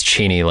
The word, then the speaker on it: Cheney: And he said, it's Cheney, like martini, but everybody says it wrong.